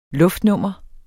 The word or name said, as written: luftnummer